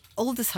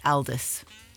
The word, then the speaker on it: Aldous: So, Aldous Huxley is pronounced like that, but Aldous Harding is spelt the same way, but she pronounces it Aldous.